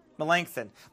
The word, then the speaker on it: Melanchthon: Melanchthon, is that how you say his name? – Melanchthon.